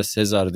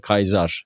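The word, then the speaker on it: Caesar: Yani Julius ayı olarak yani onu da gene hatırladığım kadarıyla Kaiser'ın yani daha çok Fransız telaffuzuyla Sezar diye biliyoruz ama şeylerde derslerde yani ilk çağ derslerinde Sezar dediğiniz zaman hani hocalar kızar e, ve Kayzar diye düzeltirler. O doğru okunuşu da o zaten Kayza.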